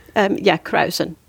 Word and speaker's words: kruisen: I'm sure I'll butcher this pronunciation. Kruisen, meaning to sail to and fro. – Krausen. – There you go.